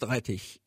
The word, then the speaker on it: Rettich: And by the way, my last name, Rettich, is pronounced Rettich in Amana German, and it means radish.